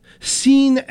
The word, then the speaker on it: Sian: This from the band Cochin and their vocalist, Sean Evans. She turned 55 last Friday. Sean is spelled S-I-A-N.